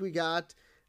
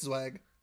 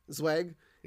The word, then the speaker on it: Zweig: This week we got Dr. Zweig, or Weg. I don't know if the Z is silent or not. – No, it's Zweig. – Zweig.